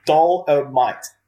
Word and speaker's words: Dolemite: Dolemite. – Dolemite. It's kind of like dynamite, which you wish you had while watching this.